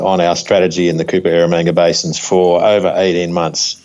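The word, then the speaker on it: Eromanga: Now, you've announced two announcements there on acquisitions in Australia in the Cooper, I don't know if you said this, I'm saying this right, Aromanga Basins. Now, is this Cooper Aromanga? – That's it. You said it perfectly, Joe.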